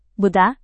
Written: buddha

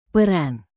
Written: peran

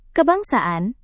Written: kebangsaan

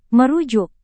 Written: merujuk

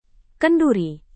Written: kenduri

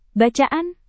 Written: bacaan

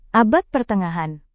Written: abad pertengahan

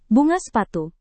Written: bunga sepatu

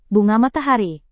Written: bunga matahari

